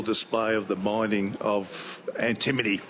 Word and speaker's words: antimony: I'd like to say antimony, was it? – Antimony. – Oh, my goodness. – Beautiful and well pronounced, too. That's fabulous.